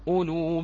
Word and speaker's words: أولو: أولو بقية، لا نقول أولو و... لا لا تلفظ هذه الألف، قلنا أنها زائدة فقط، عملها ماذا؟